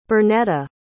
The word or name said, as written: bernetta